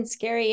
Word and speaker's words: Scarry: Elaine Scarry, yeah.